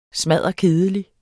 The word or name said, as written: smadderkedelig